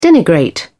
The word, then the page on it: denigrate: British English